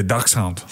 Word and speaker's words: dachshund: Are you saying dachshund? Is that how you say it? – Yeah, dachshund.